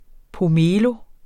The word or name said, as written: pomelo